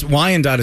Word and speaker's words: Wyandotte: And two Wyandottes? I don't know how to pronounce this. – That's Wyandotte is how it's pronounced.